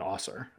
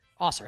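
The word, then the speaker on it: Ausar: Is that how you pronounce his name, by the way? It is, right? Osser Thompson? – Yeah, it's Amen and Osser, I think. – Osser.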